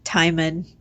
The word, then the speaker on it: Timon: This is like Timon spelt like Timon and Pumbaa, but sounds like Hymen. – But pronounced to rhyme with Hymen, exactly.